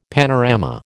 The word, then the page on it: panorama: American English